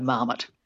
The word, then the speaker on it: marmot: So it's had a lot of time, to sort of be squished into an English model of stress, which would be the stress on the first syllable. So marmot.